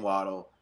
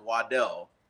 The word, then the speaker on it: Waddle: And he's like, no, call me Jaylen Waddle. That's, you know, so it is true. His original last name is pronounced Waddell, but he prefers Waddle.